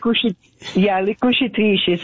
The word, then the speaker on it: Le Cucitrici: Le Cucci. I'm not saying that right. Can you say that? – Le Cucitrici.